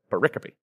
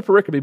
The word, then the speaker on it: pericope: Pericope. Yeah, if you say it like that, it feels better, right? Pericope. This is another pericope, but it's tied to the first pericope.